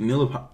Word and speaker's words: Neapolitan: Napoleon. – What's your favorite? You mean Neapolitan? – What's my favorite? – Is it Neapolitan? That's how it's actually pronounced.